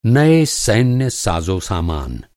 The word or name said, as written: साज़ोसामान